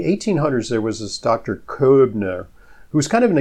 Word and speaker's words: Koebner: There's a well-known phenomenon called the Koebner phenomenon. It's spelled K-O-E-B-N-E-R. Only dermatologists know about this. Yeah, Koebner. Right, because I studied German, so I can.